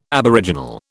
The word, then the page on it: aboriginal: British English